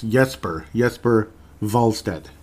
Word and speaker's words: Jesper Wallstedt: As now, I'm going to try to say that correctly from here on out. I'm not going to go to Jesper Wallstedt Jesper Wallstedt. It's Jesper Wallstedt.